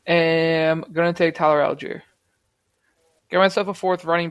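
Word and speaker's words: Allgeier: I like Tyler how do you say his name? Tyler Algier. I like him. – Yeah, Algier.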